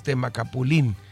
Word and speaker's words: Temacapulín: Tiene acento en la I, entonces Temacapulín.